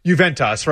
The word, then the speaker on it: Juventus: At his soccer practices, there are a couple guys, is it Fuentes? How do you pronounce it? Like the Italian, that big club in Italy. – I don't know. – Juventus or something like that. – Juventus. Yes.